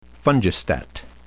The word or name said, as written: fungistat